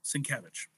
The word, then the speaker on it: Sienkiewicz: So it is, think of it as like a John Muth or Bill Sienkiewicz. Oh, yeah. Right? – So that's how you pronounce his name, is Sienkiewicz? – Sienkiewicz, yeah.